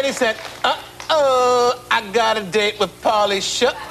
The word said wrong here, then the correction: Pauly Shore: No, come on. Say it right. – Uh-oh, got a date with Pauly Shore.